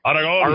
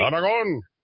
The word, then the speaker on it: Aragon: First of all, you got to make them say your name right. These leftists need to say your name right. Aragon. – Aragon. All right. Aragon.